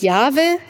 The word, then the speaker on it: Jahwe: Sie wissen wahrscheinlich, dass Gott in der Bibel einen eigenen Namen hat, von dem wir aber nur die Konsonanten kennen, J-H-W-H. h w h h das wird in der Forschung, in der Theologie teilweise ausgesprochen als Jahwe.